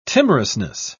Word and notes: timorousness: British English

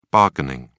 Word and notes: bargaining: British English

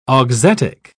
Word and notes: auxetic: British English

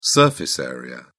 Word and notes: surface area: British English

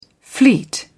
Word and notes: fleet: British English

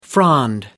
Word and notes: frond: American English

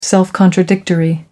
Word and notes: self-contradictory: American English

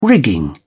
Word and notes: rigging: British English

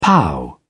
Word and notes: pow: British English

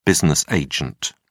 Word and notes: business agent: British English

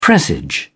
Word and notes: presage: British English

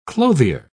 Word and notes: clothier: British English

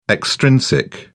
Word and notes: extrinsic: British English

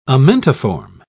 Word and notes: amentiform: British English

